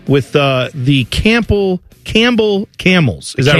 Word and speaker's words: Campbell: I'm saying Campbell because I know there's a P Campbell. – In there. – Just say Campbell. – Campbell, like the soup.